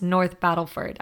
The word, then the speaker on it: North Battleford: Okay, one thing I wanted to clarify is there were a few times in the interview where I called North Battleford, North Battlefield, and so I just want to clarify that it is indeed pronounced North Battleford.